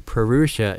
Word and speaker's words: Purusha: Okay, Purusha is spelled P-U-R-U-S-H-A.